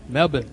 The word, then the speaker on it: Melbourne: Melbourne. – Melbourne. That's how you say it. Melbourne, not Melbourne.